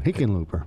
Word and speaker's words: Hickenlooper: Hickenlooper. – Hippenlooper. – Yeah, he likes to say his name. Hickenlooper.